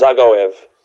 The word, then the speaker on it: Zagoev: Ankiev, Dagov, Alan Zagoev. – That's it. He's got it.